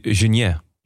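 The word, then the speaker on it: Geniets: Hoe spreek je deze naam uit, denk je? Geniet? Geniet? Het is een Luxemburger, maar mijn Luxemburg is niet zo sterk. – Michel en José hadden het op Genier.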